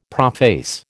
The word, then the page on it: prophase: American English